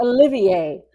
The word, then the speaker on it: Olivier: It's Olivier. Like the Olivier. But of course, the Olivier.